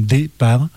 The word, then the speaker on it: DPAR: Alors ça s'écrit D-P-A-R avec 3 majuscules, mais il faut bien le lire comme départ.